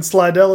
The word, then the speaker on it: Slidell: I'm, am I saying that right, or is yes, Slidell? That is correct. Not Slidell.